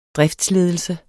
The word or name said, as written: driftsledelse